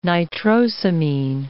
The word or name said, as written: nitrosamine